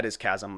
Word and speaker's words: chasm: It's spelled C-H. – Yeah. – Chasm, yeah. – Yeah. Yeah, it is chasm.